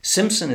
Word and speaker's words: Simpson: Peter Simpson. Simpson is spelled S-I-M-P-S-O-N.